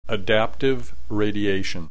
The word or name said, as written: adaptive radiation